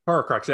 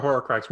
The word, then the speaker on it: Horcrux: but the fact that when the Horcrux, yeah, that's how you say it, the Horcrux,